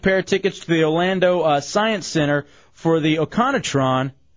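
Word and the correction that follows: Otronicon: Am I saying that right? – Yeah. – I don't think I am. Atronicon. That's how you say it. Atronicon.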